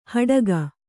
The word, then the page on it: ಹಡಿಕಿ: haḍiki